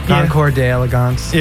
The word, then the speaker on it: Concours d'Elegance: And not this weekend, but next weekend, the Concours d'Elegance. – I know, right? – Is that how you pronounce it? – Yeah. Concours d'Elegance.